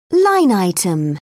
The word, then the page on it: line item: British English